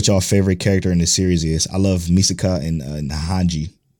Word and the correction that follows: Mikasa: Mikasa and Hanji.